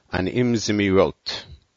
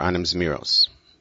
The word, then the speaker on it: Anim Zmirot: המדרגות with a very interesting version of Anim Zmirot, or Anim Zmiros. But it sounds, it rhymes if you say Hamadrigot with Anim Zmirot.